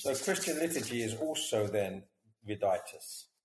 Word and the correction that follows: reditus: Viditus, how do you pronounce it? – Yeah, reditus. Yeah. Reditus. No, also, it's essentially reditus.